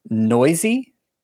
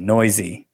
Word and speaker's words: Neuse: Sheldon Noisy. – Yep, that is how you say his name. I remember hearing it and I was like, what? – N-E-U-S-E, Noisy.